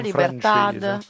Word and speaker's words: Libertad: Chissà come si pronuncia Libertà in Libertad, Libertà.